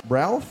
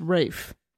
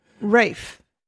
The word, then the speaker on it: Ralph: Ralph Fiennes? – Nope. Gonna stop you right there. The way you pronounce his name is Rafe. It's an old English way of saying that name.